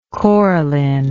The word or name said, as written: coralline